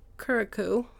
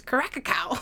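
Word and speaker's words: Curaçao: Not Curacao, like I've heard on other podcasts. Or I asked Kara how to say, I'm like, how do you say Curacao? And she goes, Caracacao.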